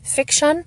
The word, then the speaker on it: Fik-Shun: His name is spelled F-I-K-S-H-U-N. Fiction.